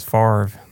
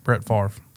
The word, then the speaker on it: Favre: Yeah, it just says the way Favre is pronounced is Favre. That makes no sense. – Yeah, don't believe it. Brett Favre. See, now it sounds right.